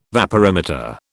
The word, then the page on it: vaporimeter: British English